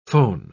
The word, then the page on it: phon-: British English